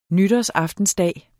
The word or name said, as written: nytårsaftensdag